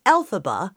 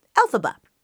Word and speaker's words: Elphaba: Sidebar, in Wicked, the Wicked Witch of the West's name is Elphaba, which is short for L. Frank Baum, if you pronounce the letters L, F, and B as a word. Elphaba.